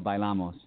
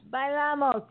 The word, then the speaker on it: Bailamos: Well, the name of the song is called Bailamos. – Bailamos, yes.